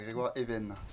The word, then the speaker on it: Grégoire Even: Est-ce que j'ai bien prononcé euh, ton prénom Ouais, c'est bien ça, Grégoire Even. – Grégoire Even, et non Even. Non, c'est américain.